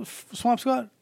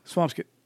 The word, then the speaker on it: Swampscott: Swamp Scott. It's Swampscott. Is this Swamp Yeah, That's how they pronounce it. Like Worcester. like Peabody.